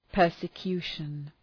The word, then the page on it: persecution: ,pɜ:rsə’kju:ʃən